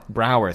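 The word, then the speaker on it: Braugher: And you know how to say his last name? – Brower. – Brower, thank you. – Yeah, it's spelled weird.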